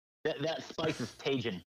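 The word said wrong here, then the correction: Tajín: Ah, it's tahine.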